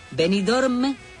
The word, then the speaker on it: Benidorm: Benidorm, hay que pronunciar la M si no, no vale, era el sueño de una noche de verano.